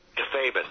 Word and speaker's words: kayfabe: They call it kayfabe now. – Yeah. – That's the if you break kayfabe, that's like if you breaking character. – Yeah. – Kayfabin, I think is the way Roddy pronounces it.